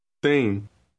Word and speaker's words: tem: Tem. – The M at the end of the word is lightly pronounced and indicates that the preceding vowel is nasalized.